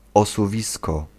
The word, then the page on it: slide: /slaɪd/